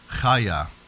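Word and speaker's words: chaya (חיה): All right, so the closest biblical word that we have for revival is chaya. Okay, that's how you say it in the Hebrew. It's C-H-A-Y-A-H, chaya.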